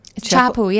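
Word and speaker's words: Chappell: How do you say it? Is it Chappelle? – Chappelle, yeah. – Ah, I was saying Chappelle.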